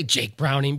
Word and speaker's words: Jake Browning: One, Dop, did you say Jake Browning because you thought it was a different name and then realized you said the name right? – No, it's literally exactly what happened.